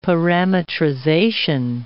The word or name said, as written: parametrization